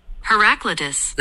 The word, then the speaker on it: Heraclitus: Heraclitus.